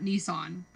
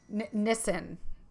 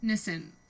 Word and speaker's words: Nissin: It's probably not Nissan, but it's spelled like Nissan. – Okay. Nissin. – Nissin.